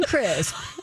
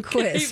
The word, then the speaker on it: Quisp: Wasn't Quisp like that? – Excuse me. – Quisp. Come on, you guys know Quisp with the pink alien? I'm not saying Crisp. I'm saying Quisp.